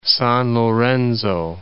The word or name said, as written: San Lorenzo